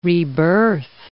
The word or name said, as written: rebirth